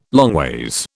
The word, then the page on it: longways: British English